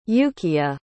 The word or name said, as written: Yukia